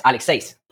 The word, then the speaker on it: Aleksejs: We're going to have like a Spanglish a little bit episode today because I've got am I pronouncing it right? – Yes, Aleksejs.